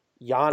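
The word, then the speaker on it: Giannis: But like I said, I got Yanni. I have Jokic. – Did you just call him Yanni? – Yeah, that's the actual pronunciations.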